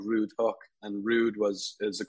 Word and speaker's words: Hoek: Um, so we're called Hoek Home and spelled H O E K, um, pronounced like hook or book.